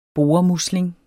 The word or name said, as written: boremusling